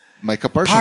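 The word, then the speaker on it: Micah Parsons: ¿Ya lo pronuncié bien, Maika? – Maika Parsons. – Parsons, sí. Claro.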